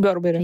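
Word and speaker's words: Burberry: а есть Барбери, Бербери, как правильно говорит? Бербери.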